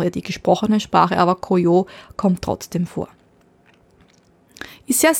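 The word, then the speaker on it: Koyo (こうよう): Die japanische Lesung ist Momiji. Momiji. Die chinesische Lesung ist Koyo.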